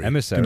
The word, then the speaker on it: Emissary: Ähm, Emissary. Emissary. Emissary. – Genau, so wird es ausgesprochen.